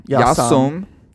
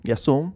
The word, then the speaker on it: Jas Sum: Is that how you say it? I know it's Yas. Yas, right? – Yasam. Yasam. – Kral. Yasam Kral.